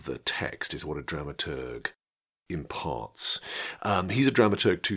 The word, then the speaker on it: dramaturg: Dramaturg? Is that how you say it? To be honest, I've never quite known. I think it's dramaturg, not dramaturge. No, I think it must be dramaturg.